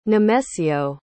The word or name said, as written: nemesio